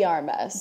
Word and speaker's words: de Armas: D'Armas. So it's like, you know, Ana de Armas.